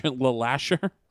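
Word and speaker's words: LeLacheur: Isn't that La Lasher? – Okay. I actually looked up how to pronounce it. I couldn't find it. So La Lasher Park? – Yes.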